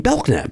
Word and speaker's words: Belknap: Well, yeah, you know that that's this, the K is silent. – In Belknap.